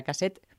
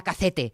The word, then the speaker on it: cassette: Me hace gracia cómo lo correctamente pronuncias, lo de la cassette, porque eh, mi infancia obetense era la cacete.